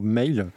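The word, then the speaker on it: Mháol: On enchaîne avec un groupe irlandais qui sort son premier album, c'est le groupe Mail. Alors, ça s'écrit M-H entre parenthèses A-O-L.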